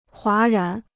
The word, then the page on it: 哗然: huá rán